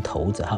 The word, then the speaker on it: 骰子: 那么连续这一个月，我们都谈到了关于这个掷骰子，就是骰子啊，这个字要正确读成骰子哈，掷骰子的玩法的这个典故，还有关于麻将的传说啊，它这来源的传说的两种说法哈。